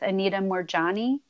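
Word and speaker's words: Anita Moorjani: I don't know if you're familiar with Anita Morjani.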